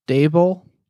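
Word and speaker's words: Daboll: Trav, how do you say the coach's name? The Buffalo guy. – Dable. Brian Dable.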